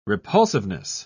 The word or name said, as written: repulsiveness